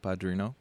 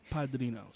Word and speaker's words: Padrino: Not bad, El Padrino. That's how you say it. – Padrino.